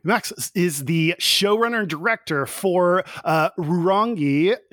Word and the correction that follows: Rurangi: No, you're not. It's Rurangi. You kind of roll your eyes, get a bit of that sort of Scottish brogue in there.